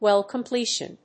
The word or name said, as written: well completion